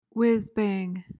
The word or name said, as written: whizbang